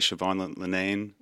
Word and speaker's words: Siobhan Linnane: We got Siobhan Linnane.